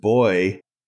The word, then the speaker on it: buoy: I think Buoy is pronounced boy in the UK. – Yes, that's correct. Yes, I've heard it pronounced that here as well.